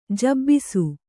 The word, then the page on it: ಜಬ್ಬಿಸು: Kannada